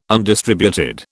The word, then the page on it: undistributed: British English